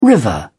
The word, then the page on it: river: British English